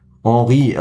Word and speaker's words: Henry: Ich bin äh, der Boss und Scorer wie Arsenals Henri oder Henry hier ausgesprochen. Henri, also Thierry Henri, äh, einer der legendärsten französischen Offensivfußballer aller Zeiten wahrscheinlich.